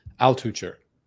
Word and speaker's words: Altucher: Altucher.